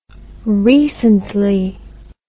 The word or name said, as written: recently